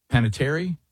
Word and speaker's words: Panettiere: Let's look at the, and I can't say her last name, the actress Hayden, is it Panettiere? – Yes, that's close enough.